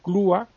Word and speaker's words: Clúa: Se llama Jaime Ferrán y Clúa, y griega Clúa, con acento en la U.